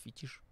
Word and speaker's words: фетиш: Ну, Мы о нем, это, кстати, вот недавно узнал, что, оказывается, правильно говорит фетиш.